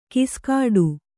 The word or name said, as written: ಗಸಿಗಟ್ಟು